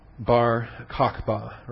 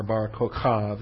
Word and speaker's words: Bar Kokhba: Bar Kokhba, or Bar Kokhav, you can pronounce it different ways.